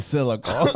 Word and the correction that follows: cyclical: I think it was cyclical.